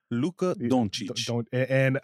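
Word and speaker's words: Dončić: Luka Doncic.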